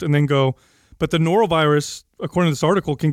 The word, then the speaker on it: norovirus: Yeah, that the Norton virus. – Norovirus. Norovirus. – Is that how you say it? – N-O-R-O, Yeah.